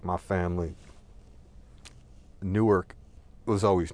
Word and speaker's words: Newark: Newark is spelled N-E-W-A-R-K, like New Ark. And you can say it properly, like Newark. Newark, two syllables, but if you're from up there, you just call it Newark. N-O-R-K, Newark, right?